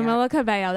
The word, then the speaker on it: Camila Cabello: Camila Cabello, that's how you say her name.